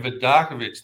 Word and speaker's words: Vidakovic: You nailed it, my friend, Vidakovic. Thank you. – Well, hey, I appreciate you being on, and I appreciate the tutorial you gave me so that I could pronounce it right.